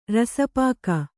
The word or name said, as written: ರಸಪಾಕ